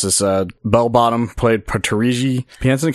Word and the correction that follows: Taraji Henson: Am I saying that right? – Taraji P. Taraji Henson. You were very close.